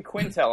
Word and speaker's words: Quintal: All right, Ryan Quintel.